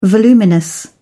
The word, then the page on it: voluminous: British English